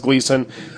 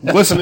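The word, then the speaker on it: Glisan: He says it should be pronounced Gleason. Glisten is the tourist way to say it. Oh, but that's wrong. Glisten is the real way to say it.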